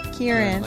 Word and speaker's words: Kieran: Karan? – What's how you say his name? – Kieran. – Kieran.